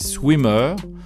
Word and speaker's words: SWMRS: Alors, le groupe s'écrit S-W-M-R-S. En fait, c'est Swimmer, hein, version j'enlève les voyelles.